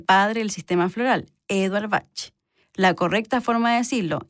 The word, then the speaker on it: Bach: Bueno, les cuento que su correcta pronunciación es Bach, con CH.